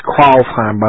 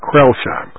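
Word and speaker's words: Crailsheim: And I was looking up, there's a McKee Barracks alumni for those who were stationed at Kralsheim, or Krellsheim as we used to call it. It's pronounced Kralsheim by the Germans, but Krellsheim. Right on the border of, Baden-Württemberg and Bavaria.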